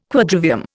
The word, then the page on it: Quadrivium: Indian